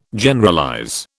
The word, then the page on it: Generalize: British English